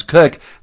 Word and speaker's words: Koch: Or my last name, spelled K-O-C-H, which we pronounce Cook, that's just bizarre, which is why I go by Dr. Kathy professionally.